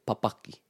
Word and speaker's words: papaki: Just with that word, titiro, whenever we have this double up in the two first syllables, papaki, titiro, pupuhi. So you got the two first syllables in a word of three syllables. The stress is on the middle one.